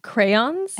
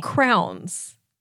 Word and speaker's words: crayons: How shall I say it correctly? – Crayons? – And what did I say? – Crowns. – I've always called them crowns.